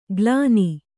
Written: ಕಲನಿ

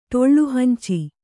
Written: ಟೊಳ್ಳುಹಂಚಿ

